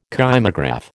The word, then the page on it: kymograph: American English